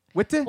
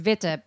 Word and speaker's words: Witte: Witte? – Well, technically it's Witte, but I think that they just pronounce it Wit.